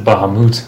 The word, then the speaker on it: Bahamut: Bahamut. – 12, they pronounce it Bahamut. – Bahamut.